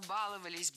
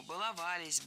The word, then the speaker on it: баловались: Баловаться, баловались, балуем, все это неправильно. В нашем глаголе «баловать», «баловаться». Ударение всегда стоит за корнем, поэтому баловались, балуем. И, кстати, если мы добавим приставки к нашему слову, то ударение все равно должно быть за корнем. Побаловаться, избаловал, добалуешься и так далее.